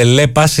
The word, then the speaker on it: Lepas: Προφέρεται Lepas, είναι λέει από Leopard, από όπου αντλεί το δυναμισμό, την ευελιξία και την κομψότητά της. Pass από passion για ανθρώπους με προσωπικότητα και πάθος για την αισθητική, την τεχνολογία και το καλό γούστο.